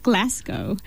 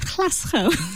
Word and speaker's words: Glasgow: It's actually called, say it properly, please. – Glasgow. – So when I was saying it, when I first said it, I said Glasgow.